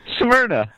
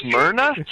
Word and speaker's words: Smyrna: Serena? Smyrna? I don't know. – Smyrna. – Smyrna? – Yeah. – Oh, my gosh. – You couldn't pronounce Smyrna? – No.